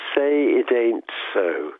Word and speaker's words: Say It Ain't So: C'est Say It Ain't So.